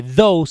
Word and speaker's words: though: Pero como persona, creo que estamos mal si ella quiere meterse a política cuando en sí no, no sabe escribir, porque Dow se escribe T-H-O-U-G-H. Dow. Y ella lo escribió T-H-O.